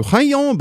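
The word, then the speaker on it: Hạ Long: While we were there, we went on a cruise through Haiyan Bay. That's actually how it's pronounced, so don't get at me.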